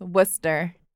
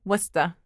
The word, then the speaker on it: Worcester: It's pronounced Worcester, or if you're local, Worcester.